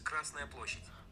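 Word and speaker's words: Красная площадь: In Russian, red square is Krasnaya Площадь. – Krasnaya Площадь. – Krasnaya Площадь.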